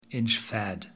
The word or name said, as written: Inchfad